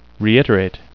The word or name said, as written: reiterate